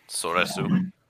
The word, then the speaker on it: Soresu: Yeah, it's just Soresu.